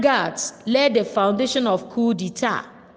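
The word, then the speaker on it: coup d'état: Now, coup spelled C-O-U-P, coup, C-O-U-P, coup. Now, d'etat, D apostrophe E-T-A-T, d'etat. You find out that the T there is silent and the P is also silent. So it is called coup d'etat.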